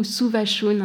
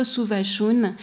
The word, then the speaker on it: Savushun: Et donc, Savushun, c'est cette œuvre-là, donc Savushun ou Suvashun, hein, il y a deux prononciations possibles. En anglais, ça a été traduit sous le titre de Savushun, l'écriture persane ne permet pas de décider. Et en Iran, on dit plutôt Suvashun, mais j'avais discuté avec un critique iranien qui me dit, possible qu'elle dise ça, Vouchoun.